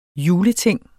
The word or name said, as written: juleting